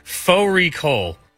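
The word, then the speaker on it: Fårikål: Fori Kol, which is a mutton and cabbage. It's spelt like Fari Kol.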